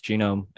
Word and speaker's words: Gnome: It's kind of like a play on, it's spelled like Gnome, but Eric was saying it's Genome because it's like the genes of the plant, whatever.